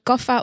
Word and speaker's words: kapha: Zeg maar, jij zegt je bent kapha of pitta.